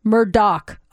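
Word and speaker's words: Murdaugh: And Alex Murda rounds out the top five. It's Alec. Oh, see, I already said it wrong. Alec Murdoch. Oh my gosh.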